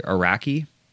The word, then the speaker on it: Araki: It was from 1995 and it was part two of a 3-part trilogy by the director Greg, or I believe he pronounces his name Greg Araki.